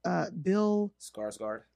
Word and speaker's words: Skarsgård: Bill Skarsgård.